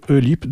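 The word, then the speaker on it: Ølyp: Il y aura les Belges de The Great White Nothing, suivi par les Belges de Meander, et pour terminer, les Français de Eulip. Enfin, ça s'écrit avec un O barré, O-L-Y-P, Eulip, donc c'est du métal progressif et de l'ambient post-metal.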